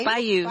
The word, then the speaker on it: bayou: The Painting on the Bio on the oh, sorry. on the why can't I pronounce that? Bio? – Bayou. – Bayou. – Bayou. – There we go.